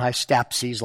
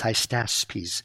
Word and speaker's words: Hystaspes: This would be Cambyses, 529 to 522, Pseudo-Smerdis, the false Smerdis, 521 to 521, and Darius I Hystaspes, not Hystapses like I keep mispronouncing, it's Hystaspes, 521 to 486.